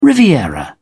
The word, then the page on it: riviera: British English